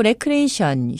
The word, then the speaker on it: recreation: elevator, 승강기, E-L-E-V-A-T-O-R, elevator, 승강기의 정확한 영어 발음은 elevator, elevator입니다. 또 recreation, 휴양, 기분 전환, L로 발음하시는 분들 많으시죠? R입니다. R-E-C-R-E-A-T-I-O-N, recreation입니다.